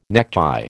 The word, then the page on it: necktie: American English